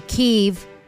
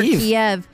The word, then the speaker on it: Kyiv: forces into Ukraine, into Kyiv. No longer Kiev. The Ukrainian pronunciation and spelling is different than the Russian pronunciation and spelling of Kyiv.